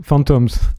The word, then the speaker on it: Fantômes: Dans l'esprit de cette collaboration, on va écouter un extrait du collectif Fantasme. Tu prononces ça comme ça, je pense, Fantasme, ouais. – Fantômes. – Fantômes, ah, c'est Fantômes. Bon, voilà, en tout cas, c'est un trio que tu formais avec Frédéric Nogré puis Lee Patterson jusqu'en 2020.